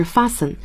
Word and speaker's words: fasten: 对，第一个 fasten your seat belts please，那么第一个是我们的安全带 seat belt，现在不管它叫 safety belt 要注意，那么系不可以用 tie 是系上一个结，我们要用的是 fasten，那么解开叫 unfasten。 – 这个 t 是不发音的，对， fasten unfasten。